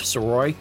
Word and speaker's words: Sirois: You can find me on Instagram and Twitter under the same name, at George Soroy, and it's spelled S-I-R-O-I-S.